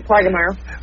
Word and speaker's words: Plaggermier: Yep, you got it right, Plagermeyer.